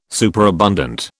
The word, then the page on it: superabundant: British English